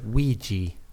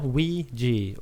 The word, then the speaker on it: Ouija: Den säger Ouija. – Ouija. – Ja, Ouija.